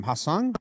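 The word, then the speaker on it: Ha-seong: Trent Grisham, center fielder, Manny Machado, Cronenworth, Luke Voit, Hosmer for now, first base, Will Myers, Austin Nola, Jurickson Profar, and how do you say Kim's first name? Hasang? – I think you got it.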